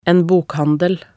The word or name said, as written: en bokhandel